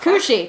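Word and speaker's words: Cù-sìth: Cushy. Cushy. There's a D in it. Not that you'd know from saying.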